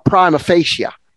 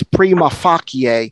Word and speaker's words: prima facie: In English, it's pronounced prima facie. Prima facie. In Latin, it's prima facie.